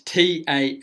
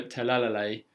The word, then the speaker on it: Talalay: So, her name is Rachel Talalay. So, it's Talalay. So, Talalay.